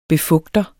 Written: befugter

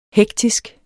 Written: hektisk

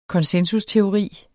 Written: konsensusteori

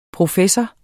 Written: professor

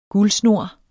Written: guldsnor

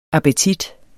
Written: appetit

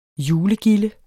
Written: julegilde

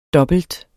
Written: dobbelt

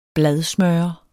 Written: bladsmører

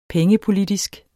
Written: pengepolitisk

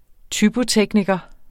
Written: typotekniker

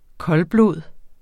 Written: koldblod

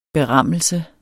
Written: berammelse

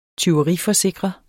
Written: tyveriforsikre

